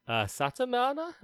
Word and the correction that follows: Saitama: or Satama? – Saitama.